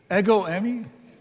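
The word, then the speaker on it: ego emi (ἐγώ εἰμι): So it's actually the emphasis is on the second syllable. So it's more appropriately pronounced ego emi. Ego emi. And so that's how you say it in the Greek.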